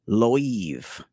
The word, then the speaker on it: Loewe: I'm pretty sure they pronounce this Loewe, okay? Although it's spelled Loewe, from the house of Loewe.